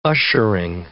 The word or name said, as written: ushering